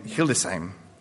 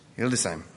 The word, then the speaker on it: Hildesheim: They've been in Germany for 34 years, 24 years in Hanover, in Hildesheim. Did I say that right? – Yes. – Hildesheim.